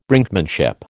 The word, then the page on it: Brinkmanship: American English